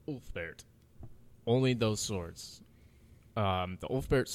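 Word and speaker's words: Ulfberht: It's U-L-F-B-E-R-H-T. – Huh, okay. – Ulfbert. – Yeah. And I watched a Ulfbert video on this, so I know it's Ulfbert. – Wow. – Because I didn't know how to pronounce it.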